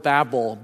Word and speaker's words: Babel: But the original beginning is called the Tower of Babel, B-A-B-B-L-E, not like it's spelled in the Bible.